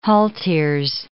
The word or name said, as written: halteres